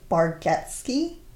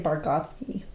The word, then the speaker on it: Bargatze: I also realized when his daughter introduced him that his last name is pronounced Bargatze. And I always say Bargatze.